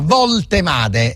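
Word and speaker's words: Woltemade: Valtemade è semplicissimo perché la W si legge V in tedesco, come sta scritto così lo leggiamo, non facciamo errori, Valtemade.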